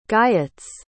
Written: guyots

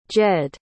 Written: jed

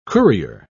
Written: courier

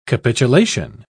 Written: capitulation

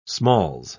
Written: smalls

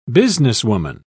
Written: businesswoman

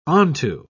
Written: onto

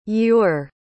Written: ure

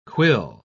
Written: quill

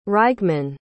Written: rygemann

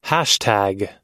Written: hashtag

